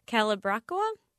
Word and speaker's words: Calibrachoa: and you're going to have to help me with pronunciation here, Calibrachoa? – Yeah.